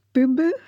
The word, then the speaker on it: bumbu: Next, we're going to talk about bumbu, is that the right pronunciation? – That's right.